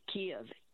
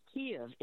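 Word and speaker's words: Kyiv: So if you know Stevie Wonder, the songs in the key of life, say it to yourself, songs in the key of life, then take off songs in the key of Kiev. Kiev is how English, you should pronounce the capital of Ukraine. It's not Kiev, it's not Kiev like the Russians.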